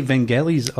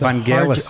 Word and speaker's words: Vangelis: It's Vangelis with Vangelis. – A hard G. I used to say Vangelis, but then, of course, when I got older, I realized it was actually a hard G.